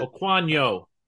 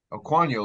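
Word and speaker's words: Okuayinonu: Sam Okwonkwo, I believe is the way he pronounces it. – Okwonyo. – Okwonyo, like I said, yes. – You threw a K in there. You're still hung up on Chig right now.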